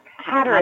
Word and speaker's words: Hatteras: Ha- Hatteras, Hatteras Girl.